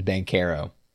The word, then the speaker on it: Banchero: Yeah, you know, when you said that, is it Banchero? I still haven't figured that out. – It's Banchero. Apparently. – Banchero, okay. – Yeah, I was listening to the broadcast on the first night of basketball. It's Banchero.